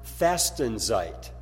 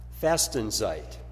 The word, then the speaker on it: Fastenzeit: And then, going further, the German word for Lent is pronounced Fastenzeit. Fastenzeit.